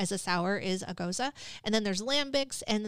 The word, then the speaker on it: Gose: Goza, which is G-O-S-E, but it's pronounced Goza.